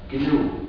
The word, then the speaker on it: GNU: Mais quand c'est le nom de notre système, il faut ne pas suivre la prononciation dans le dictionnaire. Il faut dire GNU.